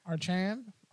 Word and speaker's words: Achane: Achane. – Achane. – I always think there's an R. I don't know why. – There is no R. – It's A-C-H. – Achane.